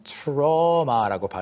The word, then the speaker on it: trauma: 우리가 그 트라우마라고 하는 게 영어로는 trauma라고 발음이 됩니다. 그래서 Trauma.